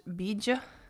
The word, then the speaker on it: Bija: And today's mantra is LAM, which is spelled L-A-M, and it's actually called a Bija mantra, which Bij is, I believe, just B-I-J, but it's pronounced Bija, and so it's often spelled B-I-J-A.